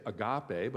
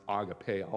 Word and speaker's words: agape: They changed it a little bit, agape, which we say agape, but it can be pronounced agape also.